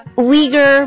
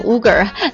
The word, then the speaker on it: Uyghur: That is to punish the Chinese government，好，这个地方断开， for its actions against the Uyghur Muslims、哦、看到这个 U 又差点念成 Uyghur Uyghur Muslims。